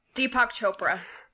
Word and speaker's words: Deepak Chopra: His name is, I'm going to butcher it because it's Indian, Deepak Chopra. – Deepak Chopra.